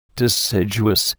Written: deciduous

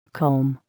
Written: comb

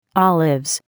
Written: Olives

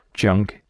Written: junk

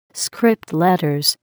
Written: script letters

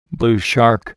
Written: blue shark

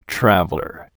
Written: traveler

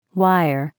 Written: wire

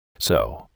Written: sew

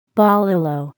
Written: bolillo

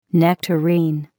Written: nectarine

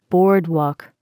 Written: boardwalk

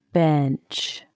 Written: bench

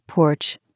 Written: porch

